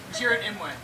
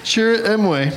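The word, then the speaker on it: Chirrut Îmwe: Chirrut Imwe. – Chirrut Imwe.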